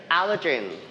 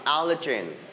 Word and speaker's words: allergen: Allergen. – Allergen. – Allergen. – Allergen. – We stretch the word on the first syllable. One more time. Allergen.